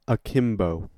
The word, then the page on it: akimbo: American English